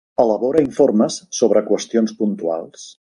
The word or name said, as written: informes